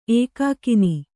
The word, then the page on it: ಏಕಾಕಿನಿ: ēkākini; Kannada